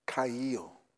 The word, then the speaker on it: chayil: The word wealth is the Hebrew word kayil. It is spelled C-H-A-Y-I-L.